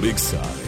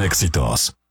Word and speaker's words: EXA: En un momento regresamos con los hijos de la noticia por Exa FM 99.7. 8:35. No se dicen éxitos. Se dice EXA.